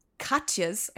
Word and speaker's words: Katjes: And this German company, Katjes, I think is the way that you pronounce it. It's K A T J E S.